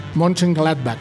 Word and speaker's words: Mönchengladbach: Y pensando en que el otro miércoles jugará su pase a Champions octavos contra el Borussia Mönchengladbach. ¿Lo, ¿Lo dije bien? Sí.